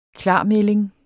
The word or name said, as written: klarmelding